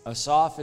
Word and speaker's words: Asaph: Asaph is probably how you pronounce A-S-A-P-H. In Hebrew, it is traditional to put the emphasis on the final syllable of Hebrew names.